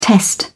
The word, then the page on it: test: British English